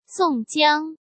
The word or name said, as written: Song Jiang